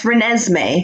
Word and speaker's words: Renesmee: Yeah, it's Renesmee. Okay. It's Renee and Esme as a name, but that's the Twilight name.